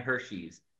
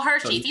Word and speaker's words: Hershey: First of all, I hear Hershey and Hershey's. How do I say this correctly? – Hershey. Well, Hershey's, so, either one.